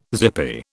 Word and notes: zippy: British English

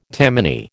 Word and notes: Tammany: American English

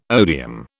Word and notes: odeum: British English

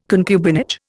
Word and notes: concubinage: Indian

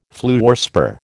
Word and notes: fluorspar: American English

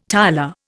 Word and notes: tiler: Indian

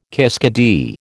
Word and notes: kiskadee: American English

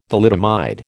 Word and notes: thalidomide: American English

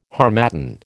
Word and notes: harmattan: American English

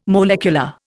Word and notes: molecular: Indian

